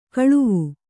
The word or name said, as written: ಕಳವೆ